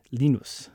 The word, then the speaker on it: Linus: So yeah, he pronounces his name Linus.